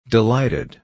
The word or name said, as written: delighted